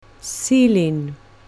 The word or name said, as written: ceiling